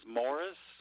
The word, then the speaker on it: Morris: My first name is spelled William, W-I-L-L-I-A-M. Last name is Morris, spelled M-O-R-R-I-S.